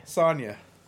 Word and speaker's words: Saoirse: S-A-O-I-R-S-E. – Sonya.